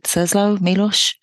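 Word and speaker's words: Czesław Miłosz: You know, the poet, I'm probably saying this wrong, is it Czeslaw Miloš? – Yes, sir.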